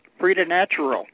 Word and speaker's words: preternatural: Preternatural.